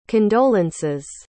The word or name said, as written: condolences